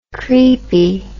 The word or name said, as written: creepie